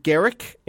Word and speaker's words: Guerric: So this comes in from Garrick in Vancouver. I think that's how you pronounce this name, G-U-E-R-R-I-C.